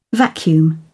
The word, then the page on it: vacuum: British English